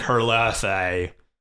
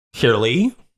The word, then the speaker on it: Herlihy: No, it's literally like Herlihy. – But there's no TH. It's just an H, so I don't know. – Herlihy? – Yeah. – Yeah. – Oh, the H is silent?